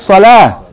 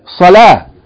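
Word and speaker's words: Salah (صلاة): That's why we normally say Salat, right? And Zakat. But if you stop on it, if you're reading that word by itself, you don't say Salat. You say Salah. Zakat. That's how you pronounce it. Salah. But if you keep reading, you say Salatul Maghrib. Now it became Ta. But if you stop on it, Salah, it's H sound.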